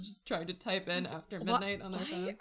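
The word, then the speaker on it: AFTRMDNGHT: It's spelled a little funny. It's A-F-T-R-M-D-N-G-H-T. So it's After Midnight, but the only vowel included in that is the A.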